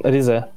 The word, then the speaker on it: Ризе: Я, как правильно ударение в названии региона ставится? – Ризе.